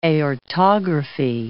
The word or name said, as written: aortography